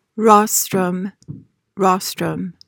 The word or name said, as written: rostrum